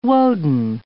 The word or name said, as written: Woden